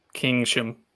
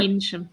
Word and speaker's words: Keynsham: It's Keensham. – Kingsham. It's all right. Kingsham. Yeah, you got it.